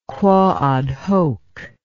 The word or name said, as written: quoad hoc